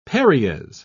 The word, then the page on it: paries: British English